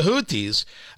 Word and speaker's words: Houthi: Of course, you have Iran backing the Houthi rebels. H-O-U-T-H-I, pronounced Houthi.